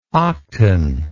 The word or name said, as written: octan